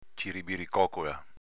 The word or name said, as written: ciribiricocola